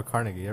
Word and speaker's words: Carnegie: Carnegie? – Yeah. I remember Carnegie. Everyone pronounced it Carnegie. – Yeah. Right. People could pronounce it however they want. – Well, Americans pronounce it Carnegie, but he's Scottish.